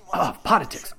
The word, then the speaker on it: Poditics: Now, that is in contrast to this episode of Politics. Poditics. Wow, that is, having... Look, this is another... This is actually good. This is good. The name of the show, of course, is Poditics. And that's spelled P-O-D-I-T-I-C-S. It's very close to the word politics, and that was intentional and continues to be intentional. It does make it difficult to say, however.